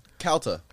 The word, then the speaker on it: Calta: Or, how do you say it? – Calta. – Calta, okay. – Calta, like California.